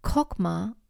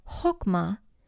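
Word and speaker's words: chokmah (חכמה): This personification of wisdom as a woman is justified not only because the Hebrew word for wisdom, pronounced chokmah or chokmah,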